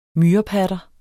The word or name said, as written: myrepatter